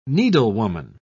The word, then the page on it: needlewoman: British English